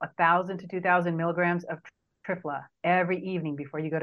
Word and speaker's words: Triphala: And so one of my favorite things is an Ayurvedic herb called Trifla, and that's spelled T-R-I-P-H-A-L-A.